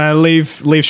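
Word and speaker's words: Liev: Leave Schreiber.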